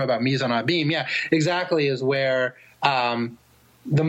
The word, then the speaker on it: mise en abîme: And you use a term, a formal term that I was unfamiliar with, mise en abîme.